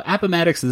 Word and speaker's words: Appomattox: And essentially, this is the Battle of Appomattox. That's how we pronounce it, right? I'm asking my Civil War. You're my Civil War. – That's the way I've always said it. Heard it is Appomattox.